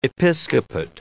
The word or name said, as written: episcopate